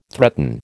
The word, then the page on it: threaten: American English